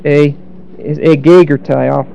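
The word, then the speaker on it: a gagertai (ἐγήγερται): Is risen in every one of these verses that refers to the resurrection really is a gagertai. I'll write the form down for those of you that study Greek. A gagertai. E-G, this is long E, the second one, E-R-T-A-I.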